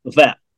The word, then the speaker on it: Lafayette: But it's like Lafayette, Alabama. – Yeah. – I say Lafayette, but for the people that live there, it's like one syllable. Lafayette. – Yeah. Lafayette.